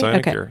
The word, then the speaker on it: sinecure: Did I pronounce sinecure correctly? – Yeah, it's sinecure. Okay. You did it good.